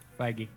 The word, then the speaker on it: Feige: D'Amaro was on tour of the Avengers Campus with President of Disney Resort, Ken Botrock, plus Kevin Feige. Is that how you say it? – Feige. – Oh, Feige, I'm sorry.